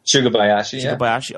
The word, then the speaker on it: Sugabayashi: ROH operating officer Joe Koff revealed a pact with Naoki Sugabayashi. Am I saying that right? – Sugabayashi. – Okay, I'm getting better.